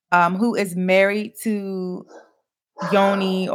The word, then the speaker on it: Jonny: it's spelled J O N N Y, right? – Yes, but it's Yoni.